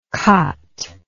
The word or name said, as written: qat